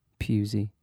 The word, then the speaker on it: Pewsey: How's it spelled? – P-E-W-S-E-Y. Pusey. – Yeah, so I'd say it, yeah.